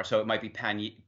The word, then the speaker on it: pannier: Pannier.